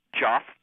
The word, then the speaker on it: Jeff: I never heard of anyone pronouncing it Joff. – Joffrey. – It was a nickname from when I moved from Michigan to Newark. And you probably had some teacher who didn't understand how to spell Jeffrey and saw it written and called you Joffrey. – So you spell it J-E-O-F-F-R-E-Y. – I spell it J-E-F-F.